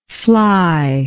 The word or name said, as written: flyless